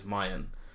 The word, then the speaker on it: Meihan: Well, they both do it, but Naoki Nakamura, its home track is Mayan, which is spelled M-E-I-H-A-N, if you want to look that up.